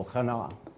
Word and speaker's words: chana: Ardor, this translation uses that word, and it's actually spelled with an O-U-R because of a British influence here. The Hebrew word there behind it is chana. It seems like the emphasis is on the second syllable, chana.